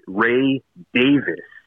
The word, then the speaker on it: Ray Davies: But Greg Kot, Jim DeRogatis, basically any music expert, they pronounce it Ray Davis, as if there's no E toward the end.